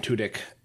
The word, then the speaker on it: Tudyk: It just doesn't feel right calling him Tudyk. – That's exactly how it's pronounced.